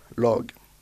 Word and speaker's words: lag: Une équipe, log. – Log. – Qui s'écrit L-A-G.